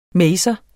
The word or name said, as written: maser